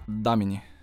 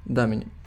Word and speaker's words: Даміні: Скажи, як правильно ім'я, щоб наголос я не Даміні чи Даміні, як правильно? Даміні. Даміні. Да, так. на перший склад.